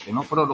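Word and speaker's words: ctenóforos: Estuvimos viendo toda la historia evolutiva desde las primeras neuronas, acordaos, en los tenidios y los tenóforos, los que llevan la C que no se pronuncia,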